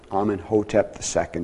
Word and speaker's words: Amenhotep II: Amenhotep II. Amenhotep II, is that how it's pronounced? Somebody help me. It is? I got it right.